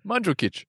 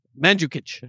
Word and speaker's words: Mandžukić: Mandžukić. – Mandžukić.